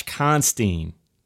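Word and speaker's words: Constine: How is his name pronounced? – Josh Constantine. – Constantine, okay, Constantine, thank you.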